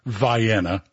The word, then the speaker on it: Vienna: Okay, I know it's Vienna, but in Georgia we have Houston County instead of Houston, Cairo instead of Cairo. So we'll go with the local pronunciation of Vienna.